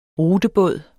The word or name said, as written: rutebåd